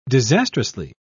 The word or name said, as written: disastrously